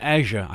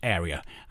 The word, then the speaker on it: Erja: I used to pronounce her Asia, I think, and I heard Tony Snyder-Denison pronounce her in a very different way as well. So I went online to the Finnish-English Dictionary and Pronunciation Guides, and it should be pronounced Area, as in, that's quite a large area over there.